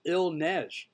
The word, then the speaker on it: Il Neige: Garrett, aka Il Nej. I just want to keep saying that just so I can finally learn how to say it. – You got it. You got it. – Il Nej.